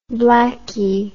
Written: blackie

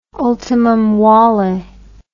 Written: ultimum vale

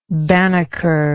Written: banneker

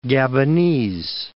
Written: Gabonese

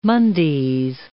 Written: Mondays

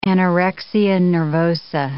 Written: anorexia nervosa

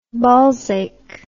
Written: ballsache

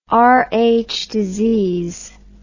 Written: Rh disease

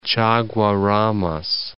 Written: Chaguaramas

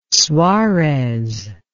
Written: suarez

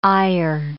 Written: eyer

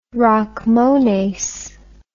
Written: rachmones

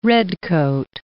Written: redcoat

